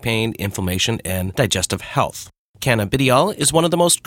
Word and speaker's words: cannabidiol: And I don't know if I'm saying this right. Cannabidiol or cannabidiol, something like that. – Cannabidiol. – There you go.